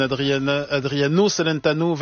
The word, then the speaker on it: Adriano Celentano: Est-ce que je prononce bien Alba Pezzon, Adriano Celentano, 24 Celentano, 24 Mirabacci.